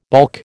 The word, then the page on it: baulk: American English